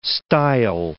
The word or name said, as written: style